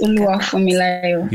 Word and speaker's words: Oluwafunmilayo: Did I get your name correctly? – Yes, Oluwafunmilayo.